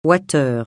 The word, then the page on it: wattheure: francais